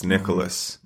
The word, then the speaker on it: Nikolas: Because my name, as you know, is spelled N-I-K-O-L-A-S, Nicholas.